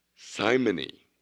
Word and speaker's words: simony: It's pronounced simony. Simony, S-I-M-O-N-Y, simony.